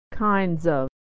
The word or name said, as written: kinds of